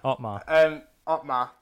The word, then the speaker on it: Otmar: Yeah, because I always say his name wrong. Omar, isn't it? Is it something like that? – Yeah, Otmar, that's it.